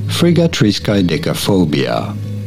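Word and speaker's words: friggatriskaidekaphobia: Frigatriskaidekaphobia. Pretty straightforward, right? Frigatriskaidekaphobia.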